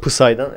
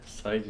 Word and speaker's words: Psy: Pısay'dan evet. – Say diyorsun değil mi? PS'siz okunur köylü.